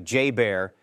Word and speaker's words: JBER: Recently, hungry bears broke into a storage room at Joint Base Elmendorf Richardson in Alaska, fittingly nicknamed J-B-E-R and pronounced like J-Bear, and helped themselves to a ready-to-eat feast.